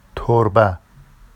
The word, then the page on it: torba: Vafsi